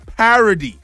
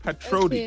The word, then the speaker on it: parody: R-O-D-Y. – All right, that is correct. That is correct, but it is pronounced parody, not patrody.